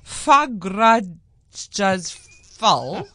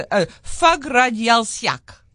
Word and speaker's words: Fagradalsfjall: So Fagradalsfjall is actually apparently how you pronounce it. It's a mountain that is erupting. And there's no C or K in this word in front of me. – But that's how you pronounce. But that's why I thought it was so interesting. So it's spelled Fagrad Alfjall. But when I Googled it, the man on the YouTube video said it's pronounced Fagradalsfjall.